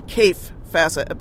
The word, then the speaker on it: Kaffe Fassett: cafe facet. I mean, it was really. I'm sorry, I did that again. Kaffe Fassett. Boy, I haven't mispronounced his name in a long time.